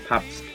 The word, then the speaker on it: Pabst: P-Bast? How do you pronounce the beer? – Pabst.